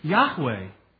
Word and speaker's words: Yahweh: Though it's pronounced, to the best of our understanding, Yahweh. And